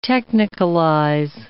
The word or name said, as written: technicalize